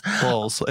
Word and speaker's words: Bowles: Bowles. It's Bowles. – Bowles. – Bowles. – Okay. Just Camilla. – Bowles. Are you kidding? – Bowles. I think it's actually pronounced Bowles in the UK, Freddie.